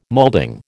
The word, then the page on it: moulding: American English